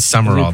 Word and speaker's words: Sumrall: It is Sumrall, though. – Is it pronounced Sumrall? – Yeah. – I feel like there's a letter missing. – It is. It's not spelled right, but that's how it's pronounced.